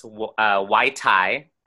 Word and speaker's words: wytai: Okay, so it's pronounced Y-tai, W-Y-T-A-I.